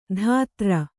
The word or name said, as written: ಧಾತ್ರ